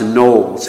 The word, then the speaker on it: Knollys: And it's spelled K-N-O-L-L-Y-S. It looks like Knollys, but it's pronounced Knowles.